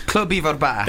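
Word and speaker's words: Clwb Ifor Bach: Club Ivor Bach. – Club Ivor Bach.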